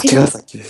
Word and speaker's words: 茅ヶ崎: これなんて読むんだっけ、茅ヶ崎って読むの、あ、茅ヶ崎です、